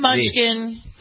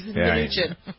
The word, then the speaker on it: Mnuchin: He's not a munchkin. He's Mnuchin.